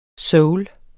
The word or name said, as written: soul